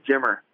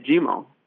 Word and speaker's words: Jimmer: Yeah, that's exactly what they were calling me, because a lot of people can't say Jimmer, so they say Jimo. You know, that's kind of how they pronounce Jimmer over there.